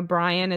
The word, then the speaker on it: Bryann: One other tip that my husband had for us was that he thinks I should spell my name because, you know, Brian is kind of, it's an unusual name for a girl.